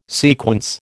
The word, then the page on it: sequence: American English